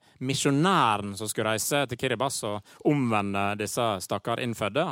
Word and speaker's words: Kiribati: Jeg hadde sagt Kiribati om jeg fikk valget, men det ikke sånn det skal sies. – Nei, det riktig, og det uttales rett og slett Kiribas.